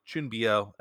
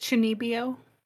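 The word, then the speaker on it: Chūnibyō: Chunbyo, and Other Delusions. – Isn't it Chunbyo? – Chunbyo, thank you.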